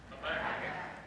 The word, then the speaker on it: Habakkuk: Habakkuk. – Again? – Habakkuk.